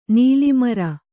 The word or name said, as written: ನೆಲೆಮುರಿ